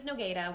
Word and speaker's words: Nogueira: So my last name is Nogueira, which is Portuguese. My dad's from Brazil. It has every vowel, N-O-G-U-E-I-R-A, so it doesn't look the way it sounds.